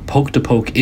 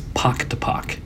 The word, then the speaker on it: pock to pock: No, poke to poke is wrong. I kept saying it wrong. It is pock to pock, the Pensacola pock to pock.